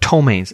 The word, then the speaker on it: ptomaine: Well, they thought that these stinky molecules called ptomaines, and by the way, PTO, ptomaine, is a silent P.